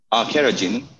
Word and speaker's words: carrageenan: And I say the word carrageenan wrong because you just said it in another way, but that's one that is in a lot of them. How did you pronounce it? – Carrageenan.